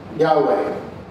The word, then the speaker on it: Yahweh: The Hebrew, if you see it written, now they didn't have any vowels, so it'd be written just YHWH, but it pronounces roughly Yahweh, the anglicized version of it anyway.